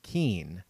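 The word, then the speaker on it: Kuenn: Of course, Roxy gets a pass because she is too young to remember, but you should know that the last name of the former Brewers manager is pronounced Keen.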